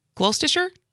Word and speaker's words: Gloucestershire: W-A-U-K. – For hundreds of years, hundreds, people have gathered in Gloucestershire, England. Did I pronounce it right? – Gloucestershire. – Yeah, now I got it. – That's better.